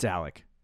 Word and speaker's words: Alec: It's Alec. – It's spelled Alec, but I know people that have their name spelled like that and they say Alex. So yeah, it's Alec.